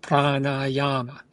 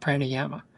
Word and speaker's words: pranayama: That's three long A's, pranayama. It's not pranayama. Pranayama.